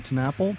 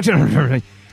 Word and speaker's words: TenNapel: Now every time I say his name, I'm scared that I'm mispronouncing it. I think that's it. TenNapel? – I think so, too. Doug TenNapel.